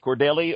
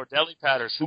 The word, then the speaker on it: Cordarrelle: And that's I'm going to pronounce his first name wrong, Cordarrelle Patterson, who was just, you know, you've got Adrian Peterson on one side, and then you've got Cordarrelle Patterson. Cordelli. Okay, thank you.